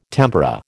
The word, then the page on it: tempura: American English